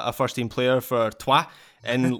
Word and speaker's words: Troyes: Let's keep you focused on Americans by talking about Erik Palmer Brown, the 24 year old center back for not Troyes, but for Troyes. I have now learned from my French pronunciation.